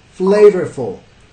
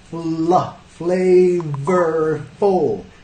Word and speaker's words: flavorful: Flavorful, ¿cuántas sílabas? Flavorful. Flavorful, flavorful. ¿Y dónde cae el estrés? Ya tenemos tres sílabas. Flavorful, flavorful, flavorful. Ya la primera más fuerte.